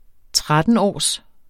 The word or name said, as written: trettenårs